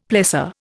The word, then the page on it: plessor: Indian